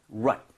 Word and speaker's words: separate: So, rate이 아니라 rut.